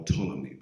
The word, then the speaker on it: Ptolemy: Or Ptolemy, because the P is silent. So Ptolemy was one. P-T-O-L-E-M-Y.